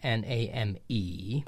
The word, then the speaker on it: name: We write name N A M E, but we wouldn't think that this word is two syllables. Oh, right. We would say name, and there's a silent E at the end, which to us looks like it serves the lengthening of the vowel, but it doesn't really because it was name originally.